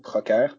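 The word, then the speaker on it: Procer: Practical Guide gives a good run at it with, you've got Callow, you've got the Procker, how do you pronounce that? – I pronounce it Procker, but French is my mother tongue, so.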